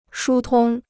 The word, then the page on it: 疏通: shū tōng